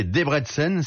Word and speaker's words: Debrecen: l'équipe de Debrecen, prononcé Debrecen,